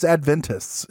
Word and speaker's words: Adventists: I say Adventists. It's Adventists is how they say it, isn't it? – Correct.